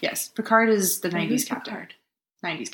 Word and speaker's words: Picard: Captain Picard.